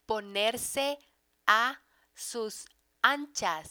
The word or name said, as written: ponerse a sus anchas